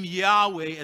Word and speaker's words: Yahweh: The name Yahweh, as we pronounce it in English, consists of a sequence of four consonants, Yod, He, Wah, and He.